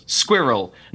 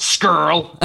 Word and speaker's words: squirrel: There's two syllables. Squirrel, not skirl.